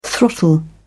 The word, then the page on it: throttle: British English